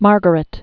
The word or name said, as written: Margaret of Anjou